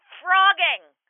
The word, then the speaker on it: phrogging: And she's like, yeah, frogging. No, frog. I think it's with a PH, but it's like F sound.